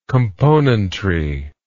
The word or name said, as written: componentry